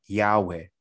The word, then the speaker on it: Yahweh: You know, whenever you see the word Lord in the Old Testament, it's actually YHWH, which we pronounce as Yahweh.